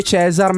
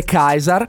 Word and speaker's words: Caesar: Attenzione, perché lui dice Cesar, ma in realtà la pronuncia sarebbe Kaiser.